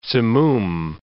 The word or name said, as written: simoom